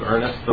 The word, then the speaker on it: Ernest: My name is Steve Ernest. The last name is spelled E-R-N-E-S-T, pronounced like the first name, Ernest.